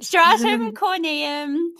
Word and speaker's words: stratum corneum: I love that you've just said barrier because sometimes there's a few different terms floating around like we hear, how did you say it? Stratum corneum. – Yes. Excellent. – Stratum corneum.